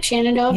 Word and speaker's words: Shenandoah: Shenandoah Valley.